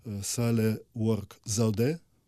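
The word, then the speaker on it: Сахле-Ворк Зеуде: Сале Уорк Залде, ви правильно поставили наголос на останньому,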